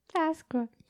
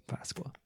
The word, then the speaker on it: Páscoa: Feliz Pascua. – Feliz Pascua. Pascua. – Páscoa. – Páscoa.